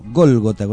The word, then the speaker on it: Gólgota: No, Golgota, con acento en la voz.